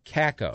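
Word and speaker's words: Kakko: So crap for Kako. – I think that's how it's K-A-K-K-O is how you pronounce the last name of the guy who's roundly projected as the second overall pick.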